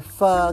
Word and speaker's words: pho: When you order your pho ga or your pho ta or whatever sort of pho, it's pronounced pho, not pho, but they are so polite they don't correct people, which polite people do not correct when people mispronounce.